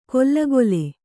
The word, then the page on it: ಕೊಲ್ಲಗೊಲೆ: kollagole